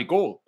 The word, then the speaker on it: Gould: I'm being very Wisconsin, so I'm pronouncing the first three letters like Gouda and then just take the LD Gould.